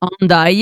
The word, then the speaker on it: Hendaye: Hendaye, I think you pronounce it. – Hendaye, yeah.